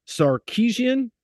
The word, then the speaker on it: Sarkisian: Sarkisian.